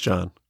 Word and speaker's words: Jahn: And I believe it's Tim John, pronounced John, correct? Because I used to say Tim Jahn, I think, or Tim John, and it's John. – Yep, it is John.